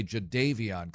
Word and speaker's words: Jadeveon: Just like it's Jadeveon Clowney, right? I still hear locals say Jadeveon Clowney.